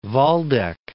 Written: Waldeck

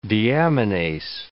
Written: deaminase

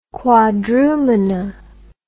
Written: Quadrumana

